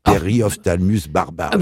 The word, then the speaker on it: Periophthalmus barbarus: Donc c'est pour ça que j'ai voulu faire un parallèle entre la Periophthalmus barbarus. En plus, quel nom, Periophthalmus barbarus.